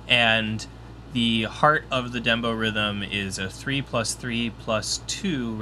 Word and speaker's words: dembow riddim: So the rhythm, the musical characteristic of reggaeton, apparently is called the dembo rhythm. – Huh. Okay. – Spelled D-E-M-B-O-W-R-I-D-D-I-M. Dembo rhythm. Which, let's see what that's all about. Rhythm is the Jamaican patois pronunciation of the English word rhythm.